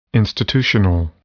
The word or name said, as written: institutional